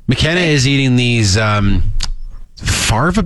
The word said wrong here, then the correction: fava: beans, they're called, or something? – Fava beans.